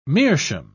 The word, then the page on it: meerschaum: British English